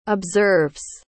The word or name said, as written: observes